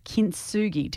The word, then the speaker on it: kintsugi: And a concept that you work at with your coaching is this idea of kintsugi. Did I pronounce it correctly? – Yeah.